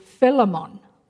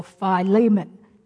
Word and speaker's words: Philemon: We have to read another book of the New Testament called Philemon or Philemon, depending on where you learned how to pronounce it.